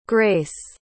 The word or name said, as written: grayce